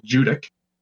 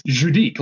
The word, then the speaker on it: Judique: And actually, when I first read the name of that town, I thought it's Judique, like I pronounced it like the way that the French would probably pronounce it. – Yeah, but of course. – But they just pronounce it Judick out there.